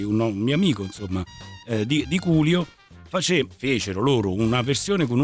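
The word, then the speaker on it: Coolio: Questo l'ho detto bene. – Culio, sì, ho detto bene. Più facile Culio, sì.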